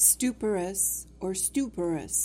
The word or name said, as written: stuporous